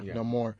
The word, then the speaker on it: Namor: Namor.